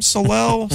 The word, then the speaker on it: Swalwell: But Eric Swalwell, is that how you say his name? Swalwell? – Close enough.